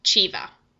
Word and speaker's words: Ceva: podcast features a short biography about a famous Italian mathematician, Giovanni Civa. Please note that Civa is spelled C-E-V-A. It is the German pronunciation.